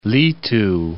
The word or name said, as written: litu